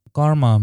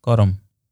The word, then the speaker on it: karma: According to many Hindu priests and scholars, karma may mean many things. First of all, we pronounce this word properly if we call it karam. Karam. In English, people call it karma.